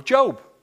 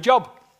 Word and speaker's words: Job: But it dropped open on an Old Testament book called the Book of Job. But it's spelt J-O-B. So if you saw that and you had no theological knowledge whatsoever, what would you think that word said?